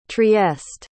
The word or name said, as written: trieste